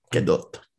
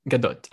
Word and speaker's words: Gadot: Gadot. – Gadot. I'm pretty sure that's how she pronounces it, because that's how she told Jimmy Kimmel it's pronounced, or Jimmy Fallon.